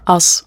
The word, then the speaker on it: Us: Und momentan veröffentlichst du auf dem Zürcher Label Us, oder wie spricht man das aus? – Us. – Us, genau.